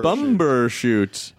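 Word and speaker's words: Bumbershoot: It sounded like you said bummer shoot. – For Matt, it is. – Bummer shoot, yeah. Is the B? Is the, you're supposed to pronounce bumber it? Bumber shoot.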